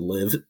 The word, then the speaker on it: LIV: The other headline I had, I don't think you guys have mentioned it before, but of course the LIV, I think it's pronounced LIV, the tour that's the big competitor to the PGA.